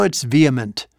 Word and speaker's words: vehement: No, it's vehement.